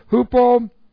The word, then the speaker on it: hupomeno: So, this is hupomeno. H-U-P-O-M-E-N, long O.